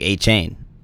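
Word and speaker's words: Etienne: But then you also look back at this offseason and Travis ETN's actual, like how you pronounce his name is like Achane. It's like Travis Achane.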